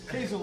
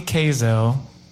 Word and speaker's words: Kazell: Kaizel like purple hazel.